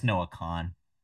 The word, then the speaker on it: Kahan: Also, I know you probably love when I correct your pronunciation, but it's just Noah Kahan. – It's Kahan. – No, it's just spelled like that. – There's two A's, so you have to pronounce both A's. Kahan. – You don't have to, actually. – If it was just Khan, there'd be no second A. It'd be Kahn.